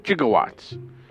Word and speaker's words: jigowatts: What's actually, according to the movie and what's ever been said about the movie, G-I-G-O. It's actually spelled J-I-G-O-W-A-T-T-S. A gigawatt is the power required to the power required is pronounced in the film as 1.21 gigawatts, with a gigawatt referring to 1 billion watts.